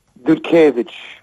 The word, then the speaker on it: Dudkiewicz: To już był sam koniec naszej rozmowy i postanowiłem wtedy zapytać go, jak wymawia polskie nazwisko Adama, gitarzysty w grupie. No, odpowiedział czyściutko. – Dudkiewicz. – Tak mnie nauczono.